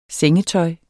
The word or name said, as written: sengetøj